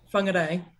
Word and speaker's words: Whangarei: Whangarei. – Whangarei.